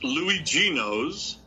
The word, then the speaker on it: Luigino's: But Luigi, a little bit more about Luigi Amo's in Montclair. – All righty. It's pronounced Luigino's, which in Italian is Little Luigi.